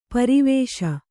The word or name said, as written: ಪೆರ್ವಸ